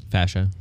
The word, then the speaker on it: fascia: Fascia. – Fascia. You knew that pretty fast. Like fascism, but not... – No, not like fascism.